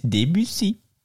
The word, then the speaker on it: Debussy: Debussy is een Fransman. En mits tien derhalve spreek je dat op zijn Frans uit. En niet als een gemankeerd vervoersmiddel. Dus het is Debussy.